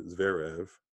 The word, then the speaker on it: Zverev: So as we see with the men's final, that took a little over 4 hours, featuring Alexander Zverev. I didn't think I was going to butcher his name like that. His name is Alexander Zverev.